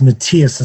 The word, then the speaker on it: Matthias: In German, it's pronounced Matthias. It's like it's Matthew. It's M-A-T-T-H-I-A-S, Matthias. And Schaeben is pronounced, it's spelled S-C-H-E-E-B-E-N, Matthias Schaeben.